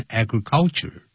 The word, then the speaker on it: agriculture: 不要念成 agriculture 它重音在第一个音节 agriculture The world over, 就等于 all over the world, across the whole world, across the world, 都可以。